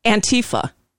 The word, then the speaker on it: Antifa: He said Rand Paul has called to subpoena how do you say it? Do you say Antifa? – Antifa.